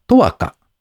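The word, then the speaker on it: 永華: BTAIL 株式会社は、インターネットで申し込みから契約まで完結できる永代供養サービス、とわか、永遠の花、華やかな花ですね、と書いてとわかと読むそうです。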